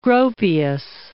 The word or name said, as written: Gropius